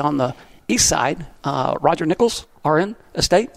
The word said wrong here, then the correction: Roger: You didn't say that right. It's Roger. – Roger. Okay. Roger, if you listen to this, I'm sorry. Yeah. I'm a Texan. I'm not very good with French.